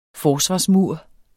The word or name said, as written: forsvarsmur